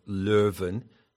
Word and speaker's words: Leuven: And it's associated with the town of Leuven, to give it the Dutch pronunciation, and strongly associated with the figure of Erasmus.